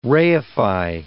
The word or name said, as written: reify